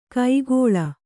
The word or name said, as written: ಕೈಕೊಳ್